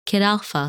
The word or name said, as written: Kitalpha